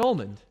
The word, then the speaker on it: almond: But the palm, the palm of your hand, palm tree, that's just no, you don't, an almond, you don't pronounce the L. – It's an almond.